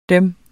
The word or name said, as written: Dem